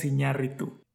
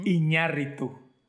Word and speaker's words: Iñárritu: directed by Alejandro Iñárritu. And I think I should be the one saying the name. – Yes, please. You go ahead. You introduce it. – Alejandro González Iñárritu.